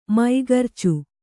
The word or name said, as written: ಮೂಗುರ್ಚಿ